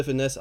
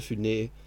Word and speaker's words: Funès: Unter anderem, oh, das ist eine gut, gutes Segway zu Louis de Finesse, der Louis de Finesse ausgesprochen wird in Frankreich und nicht de Funès, wie alle Deutschen denken. Was aber komisch ist, weil sonst das S immer weggelassen wird.